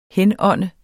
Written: henånde

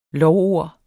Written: lovord

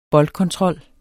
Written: boldkontrol

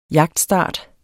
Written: jagtstart

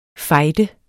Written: fejde

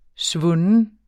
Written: svunden

